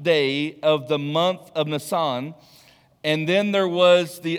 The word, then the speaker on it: Nisan: You know, if you're familiar with that word, it's spelled like Nisan, but it's Nisan.